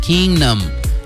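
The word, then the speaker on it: Keangnam: It's spelled K-E-A-N, Keen, G-N-A-M, Kingnam.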